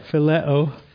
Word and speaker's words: phileo: How do you pronounce that? – Phileo.